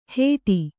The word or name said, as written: ಹೇಡಿ